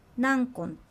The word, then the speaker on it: ナンコロ: ここでのナンコロは次の単語がななので、ナンコンと発音されます。